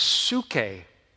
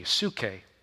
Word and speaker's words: psuche: That word is psuche. In Greek, it's spelled P-S-U-S-C-H-E, psuche. P-S-U-C-H-E. And if you're writing that, you look at that, you can see it's a form where we get a word psyche.